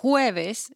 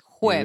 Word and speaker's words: jueves: But jueves is with a J. – Okay. – So you really have to pronounce it. Jueves. – You really gotta. Jueves it. – Jueves. – like you're hawking a loogie. – Kind of like the H in English, actually.